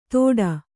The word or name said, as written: ತೋಟಾ